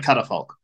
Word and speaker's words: Catafalque: Yeah, man, I had to look it up, and I've been pronouncing it wrong for years. Apparently, it's pronounced like Catafalque.